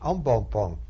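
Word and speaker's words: embonpoint: En bon pont is a French word for a very fat tummy, en bon point. But it's pronounced in English, French, en bon pont.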